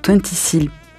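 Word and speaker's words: 20Syl: C'est la rencontre entre deux figures du hip-hop international, le français Twenty Seal. Tu confirmes la prononciation Arnaud? Twenty Seal? Ouais, il a dit oui.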